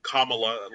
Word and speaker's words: Kamala: And then, of course, we had, you know, Maya come in again as Kamala, as we I've learned recently it's pronounced Kamala, like Kamala.